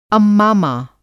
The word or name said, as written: अम्मामा